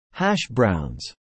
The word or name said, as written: hash browns